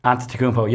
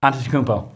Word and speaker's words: Antetokounmpo: Antetokounmpo. Giannis Antetokounmpo. – If you've seen it, I'm still doubting that's how you pronounce it. – That is how you pronounce it.